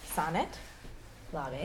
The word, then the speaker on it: Sonnet L’Abbé: Okay, so next we're gonna have Sonnet L’Abbé.